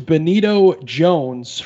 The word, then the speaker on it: Benito Jones: Number 9 is Benito Jones from Ole Miss. I said his name right, right? Yeah. – Yes.